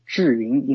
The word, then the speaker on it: ZhiYun: First, the company's name should be pronounced as ZhiYun in Mandarin, which means smart cloud in English literally.